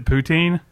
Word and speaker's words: poutine: No, but they've got, and I may say this wrong and sound perverted, is it poutine? – Poutine.